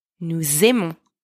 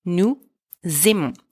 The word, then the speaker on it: nous aimons: Nous aimons, we like, we love, formal. Nous aimons. Attention here, don't forget the liaison between nous and aimons.